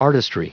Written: artistry

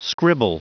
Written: scribble

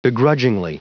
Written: begrudgingly

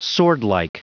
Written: swordlike

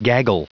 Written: gaggle

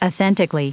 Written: authentically

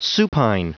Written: supine

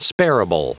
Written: spareable

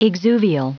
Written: exuvial

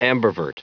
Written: ambivert

